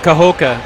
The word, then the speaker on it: Cahokia: Cahokia.